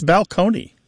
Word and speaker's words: balcony: My favorite change that I love to talk about when Martha and I go and do live events in the rows is the word balcony, you know, like this place on a stage that you can look down upon the seats, used to be pronounced balcony.